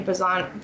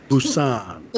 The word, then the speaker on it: Busan: But I do want to check out Train to Busan. Am I saying that wrong? – Busan. – Busan.